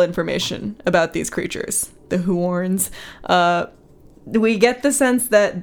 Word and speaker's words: Huorns: I'm gonna just say this the way I think it's said. The Huorns. – The Huorns. – This is spelled H-U-O-R-N-S. Huorns.